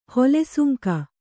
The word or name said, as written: ಹೊಲಸಂಗಿ